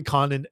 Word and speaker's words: Condon: I've heard his name pronounced two ways, Condon and Condone. I'm saying Condon. – Condon. – Right? Condon. I've heard Condone, but I love Condon.